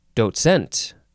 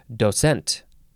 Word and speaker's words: docent: And I guess you can also emphasize the second syllable, and it's docent. And there is a little bit of a T sound. It's like there's a T in parentheses in the pronunciation guide. Docent. D-O-C-E-N-T.